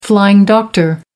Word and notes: flying doctor: American English

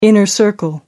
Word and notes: inner circle: American English

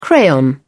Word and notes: crayon: British English

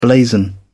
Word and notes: blazon: British English

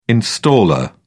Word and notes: installer: British English

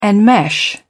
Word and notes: enmesh: British English

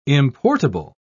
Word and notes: importable: British English

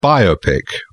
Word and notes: biopic: British English

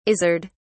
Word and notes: izzard: British English

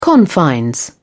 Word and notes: confines: British English

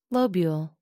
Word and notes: lobule: American English

